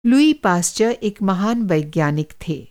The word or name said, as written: लुई पास्चर